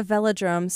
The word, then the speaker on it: velodrome: Yeah, velodrome. So it's, you see old-time photographs or videos of this sometimes, easy to find online. But there's cyclists racing around a track. So there's these velodrome racetracks all around the world, and there's one in New York City that I raced at.